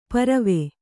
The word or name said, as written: ಪರ್ವು